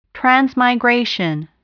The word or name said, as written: transmigration